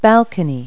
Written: balcony

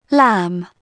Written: lamb

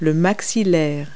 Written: maxilla

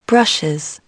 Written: brushes

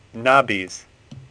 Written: Nabis

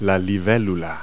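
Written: dragonfly

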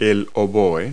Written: oboe

